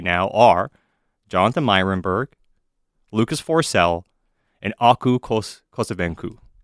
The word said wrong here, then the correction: Koskenvuo: Koskenvuo. – We're working on the pronunciation. – Aku Koskenvuo.